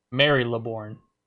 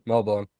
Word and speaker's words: Melbourne: Mary LeBourne. Mary LeBourne. Mary. – Melbourne.